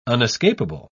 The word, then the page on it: unescapable: British English